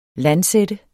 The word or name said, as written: LANDSATTE